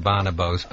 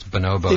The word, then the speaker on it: Bonobos: I think that is the preferred pronunciation. Although many people do Bonobos, but it's Bonobos.